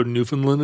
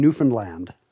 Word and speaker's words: Newfoundland: I get yelled at on Jeopardy for not saying Newfoundland. – Newfoundland. – Because here's what I found out. All those people up there say their own provincial name wrong. – Yeah, Newfoundland. – Everyone in the English-speaking world has decided how to say Newfoundland, but no one told the Newfoundlanders. – Yeah. – So they're the only ones saying it wrong, and they're so far away, I guess no one's told them.